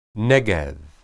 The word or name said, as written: neged